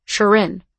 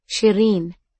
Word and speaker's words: Shireen: Most people had been saying it in two hard syllables, Shireen, which was very wrong. It was actually pronounced Shireen. I tried to explain this to him. I tried to tell him that he had to roll the R, that the whole thing was meant to be pronounced softly, gently even.